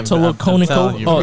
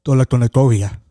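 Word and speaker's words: Tolokonnikova: Tolokonikovia. – I'm just kidding. Kovia? There's no I before the A.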